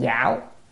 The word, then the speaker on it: giảo: Đó, súc sanh, chữ này đọc là có mấy âm, đọc âm giảo, giảo là khá.